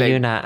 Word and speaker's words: Hyuna: Hyuna. I don't know if that's how you say her name. That's Hyena? – How I say her. It's not Hyena.